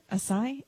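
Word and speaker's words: açaí: The pale ale is made with, is that acai? Is that how you say it? Acai? A-C-A-I? Acai? Anyway, it's called Fauci Spring. So, I think it's acai.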